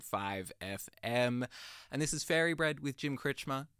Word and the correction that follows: Kretschmer: Kretschmer, I can't pronounce my own name, Jim Kretschmer, where it is about time for me to get on out of here because if you can't tell, I'm kind of tired.